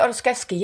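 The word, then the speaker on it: Ostaseski: I'm probably going to butcher the last name. Is it Ostesky? – Oh, Frank Ostesky. Yeah. Yes.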